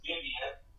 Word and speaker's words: Vivienne: But here it is, Vivian, and there it says Vivian, and Vivian, E-E-N-N-E, which ultimately Sinead wanted to spell it the French way, because, you know, we're in Cajun country, so we ended up wanting to spell it V-I-V-I-E-N-N-E, and I was vehemently opposed to it. I said, people are going to butcher it, just spell it how it's supposed to sound, whatever. She won that battle, Vivian, E-N-N-E,